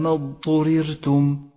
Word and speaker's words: اضطررتم: فالواجب هنا أن توضح الحرفين بالنطق هكذا إلا ما اضطررتم